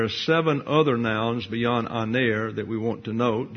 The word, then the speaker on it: aner: The Greek word is the masculine singular noun, aner. Put that up. A-N-E-R. There's that long E again, aner.